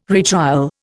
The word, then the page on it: retrial: Indian